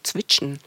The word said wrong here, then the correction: Zwitschern: Zwitschern, Zwitschern. ja.